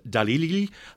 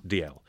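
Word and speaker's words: Dalziel: Straight off the bat, what is the correct pronunciation of D.L. and Pascoe? I've been saying D.L., but do people say it in all sorts of weird... – I've heard everything.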